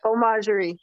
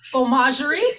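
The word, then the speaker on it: Fromagerie: No, Fomagerie, like faux. Oh. – Fomagerie, and you know, if I say something about your last name or something, I don't say it correctly, like previous one. Oh my gosh, you know, and then oh, I said it like this. And so yes, help me pronounce it correctly. It's Fomagerie.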